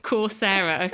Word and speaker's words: Coursera: So you have quite a lot of US universities will put on programs on Coursera. – I should have founded that, because that sounds a bit like Sarah at the end. – It's Coursera, okay.